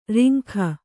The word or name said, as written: ರಂಗ